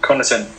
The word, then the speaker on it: Connaughton: Pat Connaughton ông ấy mình phát âm đúng không nhỉ? Ừ. Connaughton.